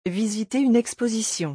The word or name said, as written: visiter une exposition